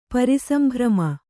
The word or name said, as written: ಪರಿಸಂಭ್ರಮ